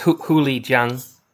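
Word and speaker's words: huli jing: It's H-U-L-I-J-I-N-G. – H-U-L-I. – Mm-hmm. – Okay, so it's I don't know what the tones are, but it's Huli Jiang. – Huli Jiang? – Close enough.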